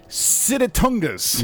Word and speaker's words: sitatunga: Sitatungas. – You got it right on the nose.